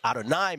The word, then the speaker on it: Adonai: Then people say Adonai Mitchell. And then, of course, those on the leaflet and those in the mishpucha, we say Adonai Mitchell, which I think is how you really should pronounce it.